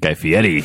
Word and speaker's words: Fieri: You gave it the official pronunciation. Guy Fieri.